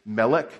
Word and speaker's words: Melech: Melech is king, M-L-K. Those are the in Hebrew you go by the consonants, and that's how you pronounce things. So M-L-K or Melech, it means king, and Zedek or Z-D-K means righteousness.